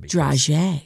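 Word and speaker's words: dragée: D-R-A-G-E-E. – Oh. – I had to look up the pronunciation. – Draje.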